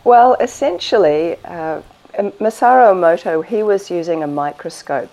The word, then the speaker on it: Masaru Emoto: But we've seen the Miyamoto, is that how you say it? The work of? – Oh, you've seen Masaru Emoto, sure. – Well, yeah. How do you say his name? – Masaru Emoto.